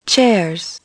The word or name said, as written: chairs